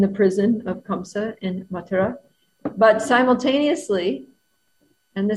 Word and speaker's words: Mathura: And usually we hear that Krishna was born in Mathura, or Mathura is the more correct pronunciation.